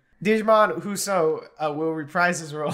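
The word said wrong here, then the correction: Djimon: I didn't say the name correctly. – Digimon Husso. – Just say Jimon. Jimon.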